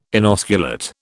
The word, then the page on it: inosculate: British English